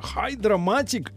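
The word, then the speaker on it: Hydramatic: Ну, для, вашей, э, для вашего удовольствия или неудовольствия, но на первом месте гидро Гидроматик. Ну, по-английски, наверное, это Хайдра. Гидра. Хайдра. Там Y, да, пишется. Хайдроматик GM.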